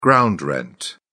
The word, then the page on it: ground rent: British English